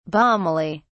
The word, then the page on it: balmily: British English